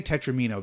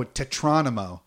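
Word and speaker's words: Tetromino: Let's see, we say Tetramino because that's correct. Oh, Tetronimo. – Yeah. – Because I was like Geronimo? That's not right. I've never heard that pronounced that way ever. – Yeah. – And we both looked at each other and went, it's Tetramino, right? Like, this isn't a tomato-tomato situation. It's Tetramino. – It's how it's pronounced.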